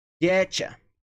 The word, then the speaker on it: get you: No entanto, quando a gente tem T mais you, soa como o quê? Olha ali em cima, cha. Então, get you fica getcha, getcha, getcha, getcha.